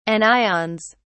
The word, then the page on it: anions: British English